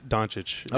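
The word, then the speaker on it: Dončić: Doncic.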